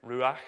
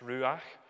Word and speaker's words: ruach: Now, the word for breath used there in verse 9, it's a Hebrew word, ruach. I've got to be careful because my old Hebrew professor is in the congregation tonight. So I think that's how it's pronounced, ruach.